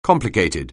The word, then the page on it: complicated: anglais